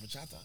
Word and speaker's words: bachata: How you say it? Bachata? – Bachata.